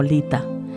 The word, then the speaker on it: Litha: Os hablo de la noche de San Juan, por supuesto, o también conocida por la tradición como Lita. Pronunciado Lit-A significa fuego.